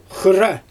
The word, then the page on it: xɯrra: Xyzyl